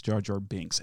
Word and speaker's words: Jar Jar Binks: Jar Jar Banks, that's it. – You can't even say his name right, by the way. – How do you say it? – It's Jar Jar Binks, and that's the only time in the world you will ever hear me defend Jar Jar.